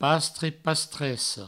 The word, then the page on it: pastre, pastressa: pastre, pastresso